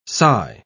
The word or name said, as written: sigh